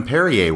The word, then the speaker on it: Perrier: My guess is they might have even drank some Evian and Pierre water. – Even Perrier water. – Oh, sorry, that, Perrier, that'd be sorry. – That's the French pronunciation.